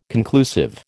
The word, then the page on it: conclusive: American English